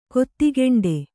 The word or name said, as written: ಕೊತ್ತಿಗೆಂಡೆ